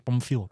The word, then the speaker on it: Pamfiel: Eh, Marcel van Wees, eh, Tim Soetens, eh, Pampiel Paring. Pampiel. Pampiel? Pampiel. Is dat de, juiste uitspraak? Ja, ik heb er ook lang moeten vragen, maar uiteindelijk is het Pamfiel.